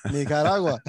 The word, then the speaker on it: Nicaragua: Nicaragua.